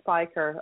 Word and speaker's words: Speicher: Yes, my name is Sara Speicher.